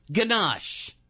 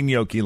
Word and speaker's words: gnocchi: It starts with a G-N, but in Italian, well, I don't want to tell you. But it's spelled G-N-O-C-C-H-I. – Ganache.